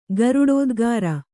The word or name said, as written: ಗರುಡೋದ್ಗಾರ